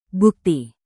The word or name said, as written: bukti